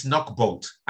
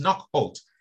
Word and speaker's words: Knockholt: He's just So he basically said, it's Knockbolt. And then people just laughing. So he said to himself, Knockbolt. And they said, no, it's not Knockbolt. The correct name is Knockholt.